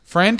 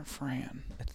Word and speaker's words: Fran: Is it Dan or Fran? – It's one of them. It rhymes with Ann.